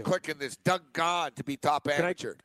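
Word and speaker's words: Ghim: So how do you pronounce? What is he said? Doug Gahan? – Gahan, G-H-A-N, is it? – Yeah, G-H-I-N, but yeah. I guess it's Gahan. He would know.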